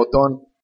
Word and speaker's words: Otón: Eso, Otón. ¿Eh? Tiene acento en la última.